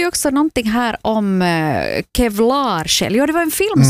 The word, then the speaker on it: Kevlarsjäl: Det ska uttalas kevlarsjäl, alltså som en pansarsjäl. Okay. Att man har så här pansar runt sin själ.